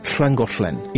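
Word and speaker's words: Llangollen: На валлийском языке есть уникальные сочетания согласных, типа 2L произносится «хл». Это очень часто употребляется в названиях валлийских городов, типа «хлангошлен» или «хланаманах».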